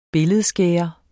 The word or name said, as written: billedskærer